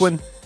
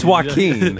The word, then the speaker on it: Joaquin: Joaquin. – Joaquin. It's a soft J. – I don't know. – I think it's Joaquin. – It's like Yagen. – It's Joaquin.